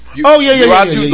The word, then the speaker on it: Uatu: I always thought Uatu, I hope I'm pronouncing that right. – Uatu, the Watcher.